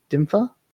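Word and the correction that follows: Dymphna: Saint Dimphna.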